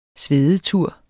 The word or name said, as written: svedetur